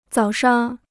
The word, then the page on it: 早上: zǎo shang